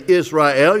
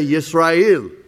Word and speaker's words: Yisrael: In other words, is you don't say Israel, you say Yisrael, just like we've been doing for 36 years.